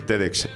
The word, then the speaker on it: TEDx: Y cuando vi la información que me llegó sobre la próxima edición del TEDx, ¿lo he dicho bien? – Perfectamente.